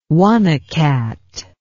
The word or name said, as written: one old cat